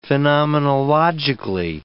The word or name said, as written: phenomenologically